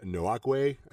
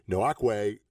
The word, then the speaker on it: Nwankwo: Like I said, Buckner and Nwankwo, I think is how you say his name. Nwankwo looked like a world beater.